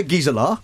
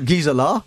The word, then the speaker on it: Gisela: Yeah, it's not really Gisella, it's Gisela, but because British people can't really say it, so I just say Gisella.